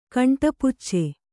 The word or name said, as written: ಕಂಟಪುಚ್ಚೆ